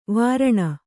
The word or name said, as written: ವರುಣ